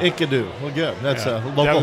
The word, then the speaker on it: Enkidu: I believe it's pronounced Enkidu. – Yeah, Enkidu. Enkidu.